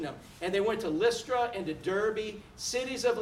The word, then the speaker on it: Lystra: Lystra, like Listerine.